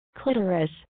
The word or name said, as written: Clitoris